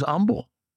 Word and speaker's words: Humble: Next thing we learn about Peter, a godly leader is humble. Has to be. In Texas, we don't pronounce the H.